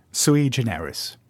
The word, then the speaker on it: Sui Generis: Sui Generis.